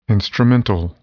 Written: instrumental

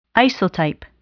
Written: isotype

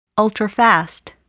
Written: ultrafast

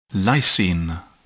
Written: lysine

